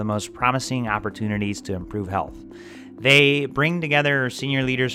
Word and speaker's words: HLTH: It's the largest and most important conference for health innovation. HLTH, pronounced health,